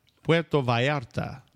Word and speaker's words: Puerto Vallarta: How would you say it? – Puerto Vallarta. – Oh, wow. I got to learn that.